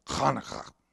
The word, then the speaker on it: Hanukkah: We see it spelled with a CH and with the guttural sounds of the Hebrew language. I can't speak like they do, but, you know, Hanukkah. So it's Hanukkah to us.